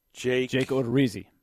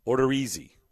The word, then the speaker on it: Odorizzi: I just saw where the Twins put Jake Ordizio. How do you say his last name? – Jake Odorizzi. – What is it? – Jake Odorizzi. – Odorizzi.